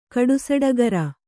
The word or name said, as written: ಕಡುಸಡಗರ